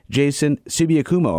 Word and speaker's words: Jason Sibi-Okumu: And on the Tiny stage tonight, we welcome Jason Sibi-Okumu.